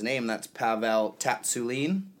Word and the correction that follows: Tsatsouline: Uh, I believe it's Tsatsouline.